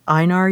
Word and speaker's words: Einar: And actually, I would recommend people read Uncle Einar, you said it's pronounced, E-I-N-A-R.